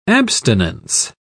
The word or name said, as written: abstinence